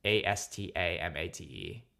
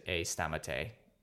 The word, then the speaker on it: Astamate: And then on Twitter, I'm at Astamate, which is, I pronounce it A Stamate. – Stamate. Yeah. Nice. – Because Stamatelaky is my last name. most people pronounce it at Astamate, but that's because like phonetically, if you're just reading it, it makes sense to say it like that. But I say it A Stamate.